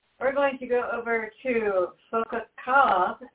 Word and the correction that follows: Fukuoka: and Kishinu. – Fukuoka.